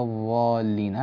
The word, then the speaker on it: الضالين: لو تتنبهون يعني أهل آية، تنبهوا مع ابنتكم إلى حرف الضاد، لا تقولوا غير المغضوب عليهم ولا الضالين، هذا صوت الضاء، دربوهم على نطق الضاد بشكل صحيح.